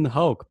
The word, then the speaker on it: Hoch: His last name rhymes with Coke, Bryan Hoch.